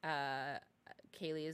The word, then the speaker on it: Keli: I don't use my first name for a lot of things because the way my name is spelled is not the typical way you would spell Kaylee. Kaylee is for me is spelled K-E-L-I. – Oh, so, that's pretty. – So when people see it, they always call me Kelly.